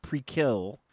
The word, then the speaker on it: Prequelle: It is um it's called Prequelle but it is pronounced prekill.